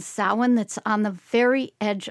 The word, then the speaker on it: Samhain: S-A-M-H-A-I-N. How do you pronounce that? – Samhain.